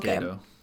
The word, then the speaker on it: Gedo: So G-A-D-O, is that? – G-E-D-O, but it's pronounced Gato.